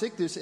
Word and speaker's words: ichthus: If you say that phrase in Greek, it's those letters right there, which is pronounced ichthus, and that's the Greek word for fish.